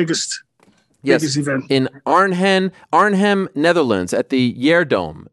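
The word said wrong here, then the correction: GelreDome: Is that, did I pronounce that correctly? – Kelderdome is called.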